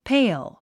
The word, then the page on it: pail: péil; ペイル